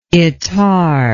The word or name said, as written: Ettarre